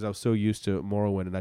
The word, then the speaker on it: Morrowind: It's pronounced Morrowind.